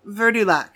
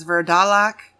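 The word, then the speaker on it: Vurdalak: Verdulac. Verdulac. So Verdulac. – Yeah, what is that? – It is also sometimes spelled as or pronounced as Verdalac or Verdulac.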